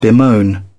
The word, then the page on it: bemoan: British English